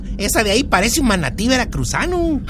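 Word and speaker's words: manatee: Manatí en inglés también se pronuncia manatí solo que se escribe con dos E.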